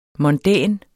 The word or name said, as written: mondæn